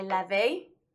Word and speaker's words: Levi: I'm, jo I'm joined here today with Rabbi Levi pronounced it correctly this time,